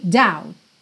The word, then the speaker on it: doubt: Essa palavra doubt é interessante porque ela tem BT no final, mas o B não é pronunciado e o T é aquela consoante oclusiva que pode aparecer mais, menos ou nada, frequentemente aparece nada, e aí você tem dow, dow.